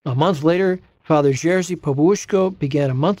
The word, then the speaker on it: Popiełuszko: His last name is pronounced Popiełuszko, and he hadn't had a very distinguished ecclesiastical career up to that point.